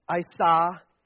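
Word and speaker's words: isha: The word for woman is, I'll say it right, is Isa, and the word for man is Ice.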